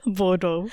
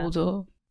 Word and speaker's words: Bordeaux: I still don't know how to say it. – Bodo. – Yeah. Bodo.